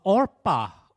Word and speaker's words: Orpah: You see, what happened there was people mispronounced Orpah as in Oprah Winfrey to Oprah so many times that she just gave up.